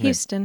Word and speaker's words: Huston: How do you say that actor's Houston.